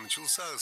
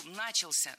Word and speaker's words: начался: Много раз прозвучал глагол «начался», но это неверно. Правильный вариант – «начался», только так. Ударение в этом слове падает на возвратную часть «ся» – «начался». И у нас на очереди еще один популярный пример.